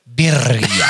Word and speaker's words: birria: Birria.